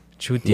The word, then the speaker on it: Chutia: I feel like I said that wrong. – But no, you got it. That was good. – Say it again. Say it again. For our new listeners. – Chutia. – Yeah, that's perfect.